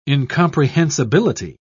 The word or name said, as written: incomprehensibility